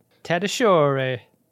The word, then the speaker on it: Tatasciore: Looks like they're heading for Tatasciore 9. – I was like, oh, that's how it's said.